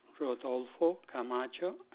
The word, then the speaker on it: Rodolfo Camacho: My first and last name, Rodolfo Camacho at Hotmail.